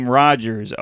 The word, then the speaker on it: Rogers: Promo code is my last name, Rogers, O-R-R-O-G-E-R-S.